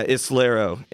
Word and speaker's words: Islero: We're here with it's Poor Islero, correct? – Yeah, Islero. It is as easy to pronounce as it looks, really.